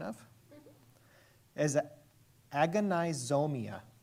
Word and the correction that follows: agonizomai: Agonizomai. We're going to go with that.